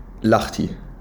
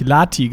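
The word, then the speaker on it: Lahti: Oh, ah, du hast es richtig ausgesprochen. Ich habe äh, Nachrichten bekommen, weil ich Lati gesagt habe und da gab es richtig viel Hate für. Da gab es so, so Leute, ich kann das nicht anhören, sag bitte das nächste Mal, lach die.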